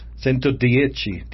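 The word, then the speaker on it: Centodieci: The Centodici, am I saying it right? – Centodici. – Like Arrivederci.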